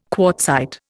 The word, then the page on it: quartzite: Indian